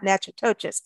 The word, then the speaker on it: Natchitoches: You're always talking about that place and I can't pronounce it, but it's Natchitoches. And it's spelled N-A-T-C-H-I-T-O-C-H-E-S, but it's pronounced, so it's not Natchitoches.